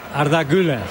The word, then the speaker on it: Arda Güler: Arda Güler.